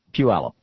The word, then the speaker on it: Puyallup: I just got an email here from a fellow in Puyallup, Washington. I'm probably mispronouncing that, too. Puyallup? Puyallup. Thank you.